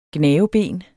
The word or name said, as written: gnaveben